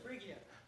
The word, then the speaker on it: Phrygia: Phrygia. – Phrygia? How would you like to come from Phrygia? Where are you from, Phrygia? I'm a Phrygianite, I guess. Anyway. Phrygia?